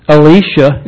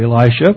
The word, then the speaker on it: Elisha: Elisha is the way they would pronounce it, but we're so used to Elisha, we'll say it that way.